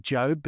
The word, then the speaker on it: Job: You know, Sebastian Job, or as the commentators pronounce it, Job, but it is spelled J-O-B, so I'm going with Job, giving his English as well.